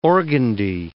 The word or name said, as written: organdy